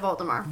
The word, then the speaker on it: Voldemort: Always said Voldemort. – Really? You just knew that it was a silent T?